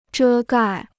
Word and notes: 遮盖: zhē gài

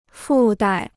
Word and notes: 附带: fù dài

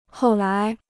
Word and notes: 后来: hòu lái

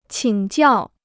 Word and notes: 请教: qǐng jiào

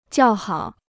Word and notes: 叫好: jiào hǎo

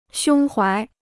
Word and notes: 胸怀: xiōng huái

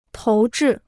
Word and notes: 投掷: tóu zhì

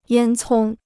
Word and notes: 烟囱: yān cōng

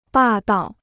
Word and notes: 霸道: bà dào

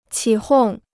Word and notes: 起哄: qǐ hòng

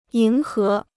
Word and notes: 迎合: yíng hé

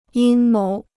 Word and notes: 阴谋: yīn móu

